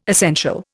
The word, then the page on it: essential: Indian